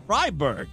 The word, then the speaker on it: Freiburg: Fry, Freiburg. F R E I B U R G is what he was trying to say.